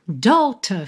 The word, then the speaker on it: daughter: Daughter, filha, D-A-U-G-H-T-E-R. Também poderia ser daughter, daughter, uma pronúncia bem comum no inglês americano. A Taylor puxou um pouquinho para British English aqui, puxou um pouquinho para Shakespeare no T, principalmente, daughter.